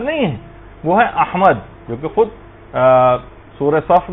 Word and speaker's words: احمد: یعنی صحیح تلفظ ہے، الف پر زبر ہے، ہا جو ہے وہ ساکن ہے، میم کے اوپر زبر ہے، اور دال جو ہے وہ ساکن، احمد.